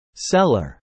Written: seller

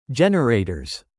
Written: generators

